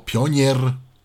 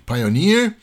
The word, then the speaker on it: Pioneer: W tym odcinku Tyflo Podcastu chciałem zaprezentować Państwu kolejne urządzenie, a mianowicie gramofon automatyczny Pioneer PL990 albo PL990 albo Pionier. PL990 albo Pioneer, zależy jak kto lubi to czytać, jak kto lubi to wymawiać. No w każdym razie fachowo się mówi Pioneer, pisze się P-I-O-N-E-R, 2 E, P-I-O-N-E-E-R, PL990.